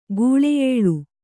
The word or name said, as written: ಗುಳೆಯೇಳು